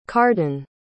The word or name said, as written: carden